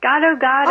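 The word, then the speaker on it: gado-gado: Is it gado gado? – Yes, gado gado.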